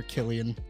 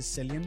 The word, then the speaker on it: Cillian: I believe that the correct pronunciation is Killian Murphy. – Mm-hmm. – I just think that when he got to Hollywood, it was like Americanized into Cillian Murphy.